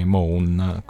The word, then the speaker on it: Mawn: che si scrive M-A-W-N, Moon, così.